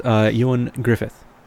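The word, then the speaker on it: Ioan Gruffudd: And yes, his name is pronounced Ewan Griffith. – Okay. – Apparently. It's since he's Welsh, you know, and it's a little bit of different pronunciation, but, you know, it works.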